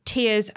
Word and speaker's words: Tears: The news comes via Eurogamer, and yes, as most of us suspected, the pronunciation is tears, as in crying tears, much like the tears you're no doubt shedding having to read yet another article on the ruddy title.